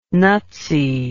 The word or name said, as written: nutsy